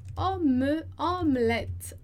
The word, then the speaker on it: omelette: Então, primeiro E é mudo e o outro é com som de E. Omelette, omelette, omelette. E o último E também é mudo, né? Porque ele não tem acento.